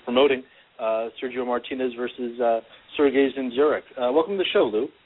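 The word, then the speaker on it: Dzinziruk: And it's nice to hear you pronounce Zinjuric right, as opposed to his two promoters who couldn't pronounce it at the press conference.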